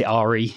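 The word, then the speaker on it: Aerea: It's a really cool palindrome, A-E-R-E-A. I pronounce it Ari. You can pronounce it however you would like. – Aria is what I keep going with. – And Aria is fine.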